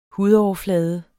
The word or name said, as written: hudoverflade